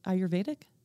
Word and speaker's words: Ayurvedic: And you didn't, you might want to try that again, actually. – Ayurvedic? – Ayurvedic. That's good. Yeah, that's good. – Okay. Ayurvedic.